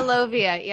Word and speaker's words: Lovia: How do you pronounce it? – Yeah, Lovia, yep.